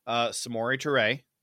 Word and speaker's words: Samori Toure: You got seventh round pick, Samori Toure, mm-hmm. which is how you say his name. I listened to him say it this morning. I had to practice it.